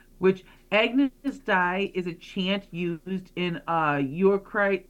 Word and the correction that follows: Eucharistic: Eucharistic. – Eucharistic. – And then, Eucharistic. – Yeah, and liturgy.